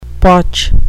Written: potch

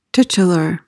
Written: titular

